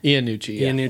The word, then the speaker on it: Iannucci: Iannucci.